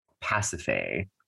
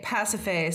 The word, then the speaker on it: Pasiphae: Okay, so Poseidon really went for it here because he made Queen Pasiphae, is I believe the pronunciation. I'm sort of tripping over these Greek names today. – I think Pasiphae sounds correct.